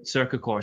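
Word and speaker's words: Cercacor: Another piece of proof that sports marketing works is I know that you're a Cerecor. Is that how you say it? – CercaCore.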